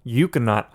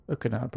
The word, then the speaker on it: Ekuanot: This IPA featuring Citra and Euconaut hops, and Euconaut, Euconaut, I don't really know how to pronounce it, but it's spelled E-K-U-A-N-O-T. – Euconaut, probably, yeah.